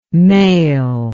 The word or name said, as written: mael